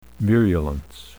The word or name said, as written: virulence